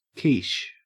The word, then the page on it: quiche: Australian English